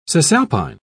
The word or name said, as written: cisalpine